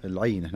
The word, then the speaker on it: العينة: إيش نطقتها قبل شوي اسمها؟ – العينة. نعم صحيح.